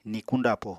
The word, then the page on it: nikuñ dapo: Apatani